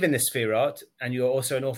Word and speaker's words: Sephiroth: That is the problem of the Sephiroth. That's the way to pronounce it.